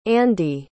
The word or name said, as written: ande